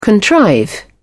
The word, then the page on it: contrive: British English